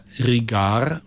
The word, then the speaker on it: Ricard: Dann sprechen wir doch mal den Namen französisch aus. Ganz einfach. Riga. Und dann hängen wir noch ein R und D dran. – Ja. – Und dann haben wir Rigard. So spricht man es im Französischen aus.